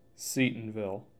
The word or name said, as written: Seatonville